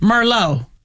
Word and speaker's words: Merlot: Yeah, remember Merlot is, and the T is silent the T on the end of Merlot.